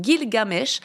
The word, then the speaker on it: Gilgamesh: Gilgamesh.